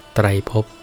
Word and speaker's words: ไตรภพ: ไตรภพคำว่าไตรภพแปลว่าภพสามหมายถึงโลกอันเป็นที่อยู่ของสัตว์สามระดับคือ 1.